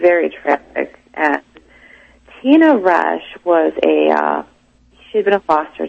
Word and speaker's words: Resch: And you write about a great case in the book, in There's Something Under the Bed, and that's the case of Tina Resch.